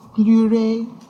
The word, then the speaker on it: pleurer: Ne me quitte pas, je ne vais plus pleurer. – Pleurer. – Je ne vais plus pleurer. – Pleurer. – Ah, it's like il ne pleut pas.